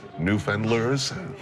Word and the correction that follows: Newfoundlanders: Newfoundlanders, Newfoundlanders see themselves?